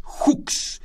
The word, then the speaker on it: Hux: Entonces, en una de estas perforaciones, el pozo se llama Hooks, se escribe H-U-X.